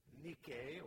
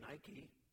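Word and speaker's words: Nike (νίκη): It's that Greek word Nike, or as it's pronounced in the business world today, Nike.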